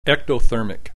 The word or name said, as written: ectothermic